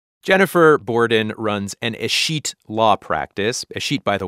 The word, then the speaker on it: escheat: First thing I needed to ask you is, how do you say escheat? – Escheat.